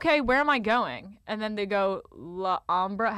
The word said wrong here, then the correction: Alhambra: Oh, Alhambra. – Alhambra.